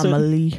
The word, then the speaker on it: Amalie: Like apparently, because like I think we were at the Amalie Arena, I believe is how you pronounce Amalie.